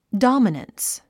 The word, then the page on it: dominance: dάmənəns; ドォマナンス